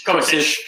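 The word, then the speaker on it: Kovačić: Kovacic.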